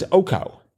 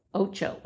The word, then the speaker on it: ocho: And that was oco. – Ocho.